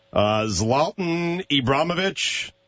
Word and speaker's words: Zlatan Ibrahimovic: Zlatan Ibrahimovic.